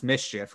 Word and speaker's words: MSCHF: I mean, the article also mentioned that this particular company, MSCHF, I think it's pronounced Mischief because that's what the letters spell out, but they said they also made heaven theme.